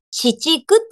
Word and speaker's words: 紫竹: 紫の竹と書いて、紫竹って読むんですけど、紫竹ガーデン正解です。おめでとう、オメグ。そしてヒロさん惜しい。ヒロさん惜しかった。もう一歩、あと 1 分早く、 1 分っていうか何秒差のタッチの差でしたね。そうなんですよ。紫竹ガーデンなんですよ。